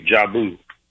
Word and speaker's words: Jabu: Just like Jabu the Hutt, but with a long U, Jabu.